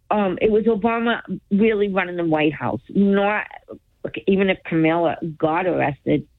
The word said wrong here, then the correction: Kamala: Kamala. – Kamala, right. I was so good for so long. Like a whole year I said her name right.